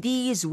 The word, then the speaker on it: these: 하나는 this, 단수는 this, T-H-I-S. Mm-hmm. 짧게 this. – This. – 근데 여러 개면 복수일 때는 좀 길게 하셔야 돼요. These.